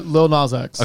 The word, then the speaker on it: Lil Nas X: How do we pronounce his name officially? – Lil Nas X.